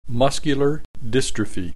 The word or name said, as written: muscular dystrophy